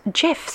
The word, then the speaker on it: GIF: Technically, it is pronounced GIF, so you're right. But most people say GIF still.